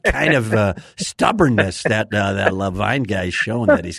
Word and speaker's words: Levien: First of all, his name is, we pronounce the name Levine, but Well, then they got to put the E on the other side of the N because it's spelled L-E-V-I-E-N. – I agree, man. He's been my best friend since we're 14, 15 years old, and I've been telling him for a long time that name is Levian. But he insists on calling it Levine, something about honoring the way his dad and grandfather, great-grandfather all pronounced it.